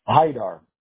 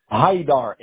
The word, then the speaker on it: Haidar: Haidar. – What? – Haidar, H-A-I-D-A-R, Haidar. – H-A-R-D-I-R? – H-A-I-I as in indigo. – Uh-huh. – D-A-R.